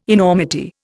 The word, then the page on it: enormity: Indian